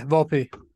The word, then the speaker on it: Volpe: Anthony Volpe, is that how you say it? – Yeah, Volpe. Yep.